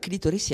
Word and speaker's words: clítoris: En español. Clítoris con acento en la I.